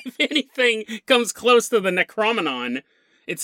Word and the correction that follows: Necronomicon: this thing or the Necronomicon.